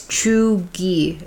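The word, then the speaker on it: cheugy: It's Chuegy. It says to pronounce it that way.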